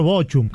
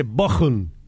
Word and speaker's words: Bochum: Y me dice, bien pronunciado, con CH, entre dos vocales se pronuncia como J. ¿Vio que hablo alemán? – Bueno. Claro, yo lo hubiera dicho Bochum, creo, claro.